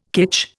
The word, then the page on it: kitsch: Indian